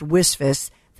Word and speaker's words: WSFS: WisFist, WSFS, we stand for service, pronounced WisFist.